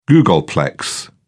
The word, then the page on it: googolplex: British English